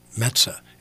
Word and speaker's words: Metza: I go, Metza. It's like the New York Mets with an A at the end.